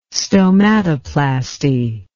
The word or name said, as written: stomatoplasty